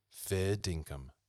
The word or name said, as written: fair dinkum